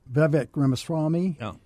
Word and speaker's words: Vivek Ramaswamy: And uh, uh, Vivek Ramaswamy.